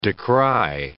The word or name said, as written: decry